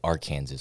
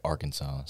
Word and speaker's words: Arkansas: It's kind of weird, you know, how we say Kansas, but we don't say Arkansas. We say Arkansas.